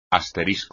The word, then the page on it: asterisco: espagnol